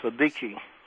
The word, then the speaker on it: Siddiqi: I'm probably not saying his name right. Sadaki. – Muzammil Sadiki.